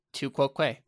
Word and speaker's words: tu quoque: Tu quoque. Let me see if I can figure out how to pronounce this. Yeah, I pronounced it right. Yeah. Tu quoque.